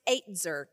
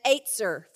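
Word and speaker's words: eitzer (עזר): The word is E-Z-E-R in the Hebrew, and it's pronounced eitzer, kind of like the number eight in a Z-U-R, an eitzer for him.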